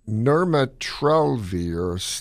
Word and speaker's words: nirmatrelvir: Is this a It's nirmatrelvir / ritonavir.